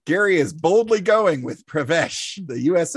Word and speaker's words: Pravesh: My ship is the USS Pravesh. – Spell it. – P-R-A-V-E. That's V as in Victor. E-S-H. Pravesh.